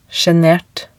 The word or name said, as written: sjenert